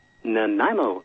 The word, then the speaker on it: Nanaimo: In Mimino, Canada. Is that right? Mimino? – Nanaimo. – How do you say it? – Nanaimo. – Nanaimo. We're not even close here.